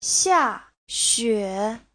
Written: xia4xue3

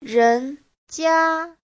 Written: ren2jia1

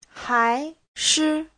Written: hai2shi5

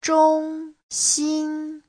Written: zhong1xin1

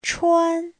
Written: chuan1

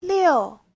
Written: liu4

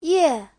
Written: ye4

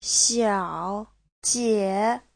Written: xiao3jie3

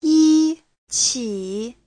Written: yi1qi3

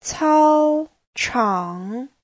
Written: cao1chang3

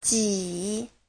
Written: ji3

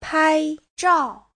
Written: pai1zhao4

